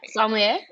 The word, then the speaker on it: sommelier: I accurately know that I do not pronounce sommelier. Sommelier, which is it? Correct? Sommelier. Yep.